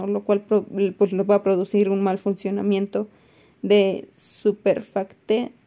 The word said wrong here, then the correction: surfactante: perdón, no lo puedo pronunciar bien, surfactante pulmonar. Ahora sí.